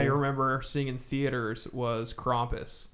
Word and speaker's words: Krampus: I think it's Krampus. – Yeah, it's pronounced it's Krampus. Krampus. It's German. Now, oh, that's Belschnickel.